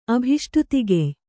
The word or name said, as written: ಅಭಿಷ್ಟುತಿಗೆಯ್